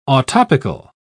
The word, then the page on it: autoptical: British English